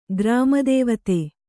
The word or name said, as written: ಗ್ರಾಮದೇವತೆ